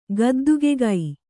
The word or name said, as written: ಗದ್ದುಗೆಗೈ